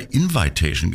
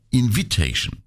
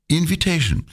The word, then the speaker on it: Invitation: Ich habe immer Invitation gesagt. Es heißt aber Invitation. Also merke, es heißt zwar Invite, aber Invitation.